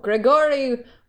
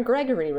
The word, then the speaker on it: Grigori: Okay, so Grigori Rasputin, or Gregory Rasputin, if you're pronouncing it the American way.